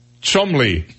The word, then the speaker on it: Cholmondeley: it's spelled Cholmondeley. Mm-hmm. But it's pronounced Chumley because of, you know, Pawn Stars.